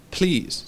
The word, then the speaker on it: please: Y se dice please. Se escribe P-L-E-A-S-E, pero se pronuncia con una I. Please. Y la S no es tan fuerte, ¿eh?